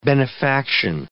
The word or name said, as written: benefaction